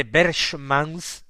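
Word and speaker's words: Berchmans: Eh, su apellido Berman se escribe con CH, se pronunciaría literalmente Berchemans, terminado en S.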